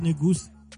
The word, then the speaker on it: Negus: He says it's pronounced Negus, as in with a goose.